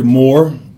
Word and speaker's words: Moore: Sure, Howard Moore, M-O-O-R-E.